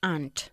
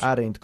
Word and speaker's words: aren't: Aren't. – Eso es. ¿Y cómo lo pronunciaste tú? – Aren't. – Aren't. Como todos los españoles. No se hace así. Aren't, ¿ok? Una okay. sola sílaba.